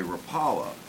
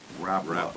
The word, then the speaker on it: Rapala: And because I'm from the Midwest, originally from there, I learned to say Rapala, but the family name is Rapala. – Rapala is the way they pronounce it.